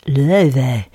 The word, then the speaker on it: Laufey: That's Icelandic Chinese jazz singer spelled L-A-U-F-E-Y but pronounced Löve.